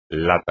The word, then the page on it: lata: espagnol